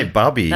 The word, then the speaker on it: bubby: So Roy spelt it and this B-U-B-B-Y, which I think I would say bubby. – That's bubby. – So I think I maybe spell it B-U-B-I.